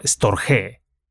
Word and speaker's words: storge: Los griegos llamaban a este amor storge, dos sílabas, y la G es fuerte.